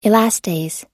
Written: elastase

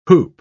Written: hoop

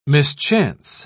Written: mischance

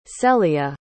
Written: selia

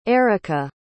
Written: eirica

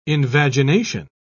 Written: invagination

